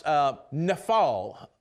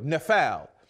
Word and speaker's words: nafal: Now the word fall there is pronounced nafal, in Hebrew, nafal. And that's the original word there in the Old Testament, nafal.